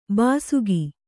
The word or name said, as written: ಬೇಸಿಗೆ